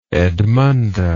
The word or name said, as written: edmunda